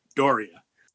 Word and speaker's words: D'Auria: I say it as Doria.